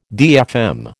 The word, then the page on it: DFM: American English